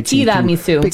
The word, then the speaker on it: Tiramisu: You were right. You said it right. – Tiramisu.